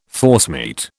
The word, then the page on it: forcemeat: British English